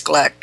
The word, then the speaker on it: Gleick: Chaos, Making a New Science, national bestseller, James Gleick. It's spelled G-L-E-I-C-K, Gleick.